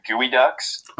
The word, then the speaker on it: geoduck: Out in Olympia, Washington, it's spelled G-E-O duck, so it's spelled like geoduck, but it's actually pronounced the Gooey Ducks.